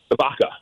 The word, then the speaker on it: Vaca: I hope I said that right, didn't I? Vaca? – Vaca, yeah. Yes. Vaca, yeah, like okay. Like cow in Italian.